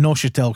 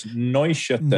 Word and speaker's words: Neuchâtel: Well, I went with Neuchâtel because I wasn't sure how to pronounce it. – You're not even saying that right. It's Neuchâtel.